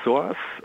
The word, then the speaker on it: SOAS: Also die SOAS, das ist, so wird die Abkürzung ausgesprochen,